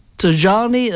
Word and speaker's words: Tajani: And then it's, Georgia Meloni, it's M-E-L-O-A-I. And the second one, Antonio, is Antonio. Even I know how to pronounce that. Tajani, that's T-A-J-A-N-I. – Yeah, Tajani. – And C, Elie Schlein, it's S-C-H-L-E-I-N.